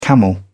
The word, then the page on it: camel: British English